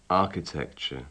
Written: architecture

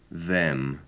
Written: them

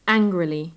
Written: angrily

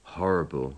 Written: horrible

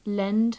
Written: lend

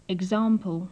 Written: example

